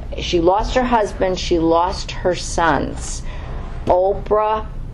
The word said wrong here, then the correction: Orpah: did I say that right? No, I said it wrong. I always do this. Orpah, thank you.